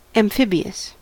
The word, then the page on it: amphibious: American English